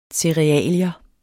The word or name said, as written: CEREALIER